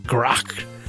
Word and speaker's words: GR8: By the way, GR8, in quotes, is pronounced great in English. In Dutch, it's pronounced grok, which means canal, and therefore is a pun.